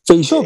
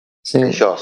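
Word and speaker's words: Feijóo: Asumió Feijó, no sé cómo se pronuncia. – Feijó, creo que es un Núñez Feijó. – Feijó, sí.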